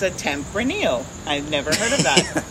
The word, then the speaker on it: Tempranillo: Tempranillo? – A Tempranillo. – Tempranillo, is that how you say it? – Yeah.